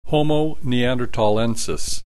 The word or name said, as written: Homo neandertalensis